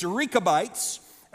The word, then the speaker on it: Rechabites: I know in the English you would only say the E as a short E, like wreck or wretch, but in the Hebrew it's Rechabites, and